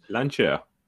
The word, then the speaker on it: Lancia: and Lan- Lancia, Lancia Lancia. Yep. Say it right, James. Lancia.